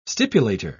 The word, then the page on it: stipulator: British English